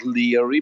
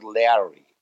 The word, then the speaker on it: Leary: He was pronouncing in the ancient way, not Leary, but Leary.